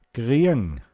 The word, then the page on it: kriang: Pinzgauer Mundart